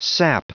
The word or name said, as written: sap